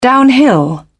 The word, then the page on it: downhill: British English